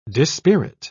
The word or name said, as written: dispirit